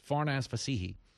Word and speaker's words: Farnaz Fassihi: A New York Times reporter, Farnaz F- Fassihi, I want to make sure I'm pronouncing that right. It's Farnaz Fassihi.